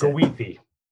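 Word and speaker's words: Goethe: I always fuck up his name. – It's pronounced Gawithi.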